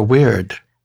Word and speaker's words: wyrd: He used to say early on, follow your weird. And that is spelled W-Y-R-D.